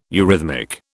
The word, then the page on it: eurhythmic: British English